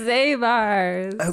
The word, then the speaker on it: Zabar's: It's pronounced Zabar's.